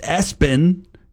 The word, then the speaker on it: ESPN: The name was ESPN, pronounced Espen.